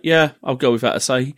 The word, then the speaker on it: Asahi: I think it's pronounced Asahi, but I'm not certain. Yeah. It's Asahi. – Okay.